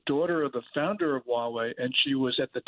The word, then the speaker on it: Huawei: And what about the, what is it, Mawa? Am I pronouncing it correct? The phone company? – Huawei.